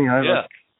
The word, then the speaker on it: Iovine: And I think that was the point of when James – I never knew how to pronounce his name. I think it's Iovine? – Jimmy Iovine.